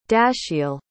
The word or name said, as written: dashiell